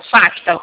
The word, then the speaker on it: FACTA: Vai escrever F-A-C de casa, T de tatu, A de amor, FACTA. O C é mudo.